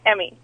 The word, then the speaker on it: Emi: Emmy. – Emmy. Okay. – Emmy. – Is it really spelled E-M-I? – Yeah.